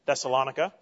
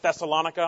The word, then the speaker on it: Thessalonica: By the way, there is great debate about the pronunciation of Thessalonica. I learned this yesterday. I was listening to Pastor Bob's message during Sunday school last week. He pronounces it Thessalonica. I have always pronounced it Thessalonica. Great debate about this. Apparently both of them are accurate.